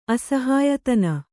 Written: ಅಸಹಾಯತನ

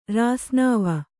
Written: ರಾಸ್ನಾವ

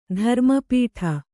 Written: ಧರ್ಮಪೀಠ

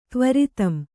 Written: ತ್ವರಿತಂ

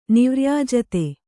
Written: ನಿವ್ರ್ಯಾಜತೆ

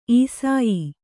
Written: ಈಸಾಯಿ

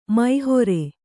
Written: ಮೋಹರಿ

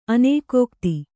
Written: ಅನೇಕೋಕ್ತಿ

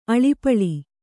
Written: ಅಳಿಪಳಿ

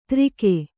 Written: ದಾರಿಕೆ

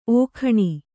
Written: ಓಖಣಿ

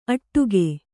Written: ಅಟ್ಟಗೆ

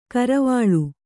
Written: ಕರವಾಲ

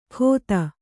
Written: ಖೋತ

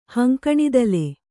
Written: ಹಂಕಣಿದಲೆ

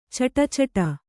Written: ಛಢಾಛಡಿ

